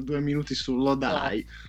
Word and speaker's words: Lodi: Tra l'altro Lodi si pronuncia Lodai.